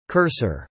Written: cursor

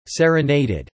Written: serenaded

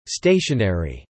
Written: Stationary

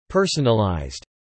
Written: personalized

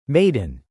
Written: maiden

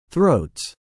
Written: throats

